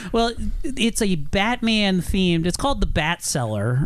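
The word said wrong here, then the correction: Batskeller: Right? – I think it's pronounced Bat Skeller.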